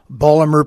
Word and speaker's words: Baltimore: I'll pronounce it in Baltimoreese, okay? – Uh-huh. – To please H.L. Mencken. It'd be Balmer Prefall, which is nothing but Baltimore profile in Baltimoreese.